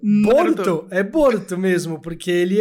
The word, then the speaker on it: Boruto: E aí é só o jeito que japonês pronuncia Bolt. É Boruto.